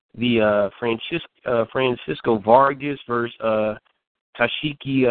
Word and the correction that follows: Takashi: uh, Meliora. I- I- am I saying that right, uh, Roberto? Takashi. Takashi. Takashi. Uh, Takashi. Um, Miura, round nine.